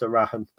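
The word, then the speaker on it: Erhahon: We spell it G-O-D. It's just pronounced Arahan, but yeah, we spell it G-O-D.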